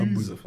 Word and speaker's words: Абызов: Абазов или Абызов, честно говоря, даже не знаю, как его правильно говорить. Мне кажется, Абызов. Наверное, дурак. – Я бы Абызов. – Абызов. – Абызов он оказывается.